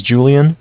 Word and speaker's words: Julian: My name is Julian. It's J-U-L-I-A-N. – Mm-hmm. – And I'm calling from Bank of Cardiff. It's spelled C-A-R-D as in dog, I-F-F as in Frank Frank.